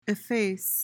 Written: efface

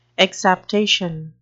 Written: exaptation

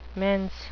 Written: mense

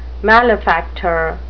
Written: malefactor

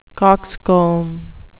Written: coxcomb

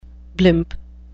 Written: blimp

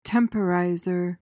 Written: temporizer

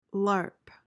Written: larp

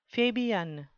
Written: fabian

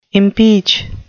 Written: impeach

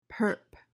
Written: perp

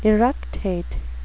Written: eructate

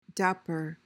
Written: dapper

